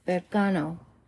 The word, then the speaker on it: Berkano: All right, so the next one we've got is Berkano.